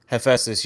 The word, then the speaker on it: Hephaestus: Hephaestus,